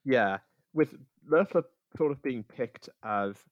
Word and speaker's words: Loeffler: I've been going with Loeffler, like Loeffler, like it's German.